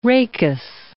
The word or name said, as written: rachis